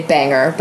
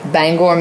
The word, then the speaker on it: Bangor: I went to, I'm going to say it wrong because I say Bangor, but it's Bangor, Maine.